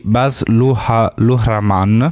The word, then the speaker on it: Baz Luhrmann: Allora, invece io continuo con Elvis, che è un film di Baz Luhrmann.